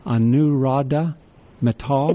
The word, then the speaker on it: Anuradha Mittal: His name is spelled A-N-U-R-A-D-H-A-M-I-T-T-A-L. I believe it's pronounced Anuradha Mittal. – It's Anuradha Mittal.